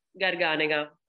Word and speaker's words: Garganega: Garganiga. – I do. Garganiga.